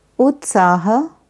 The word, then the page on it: Utsaha: Sanskrit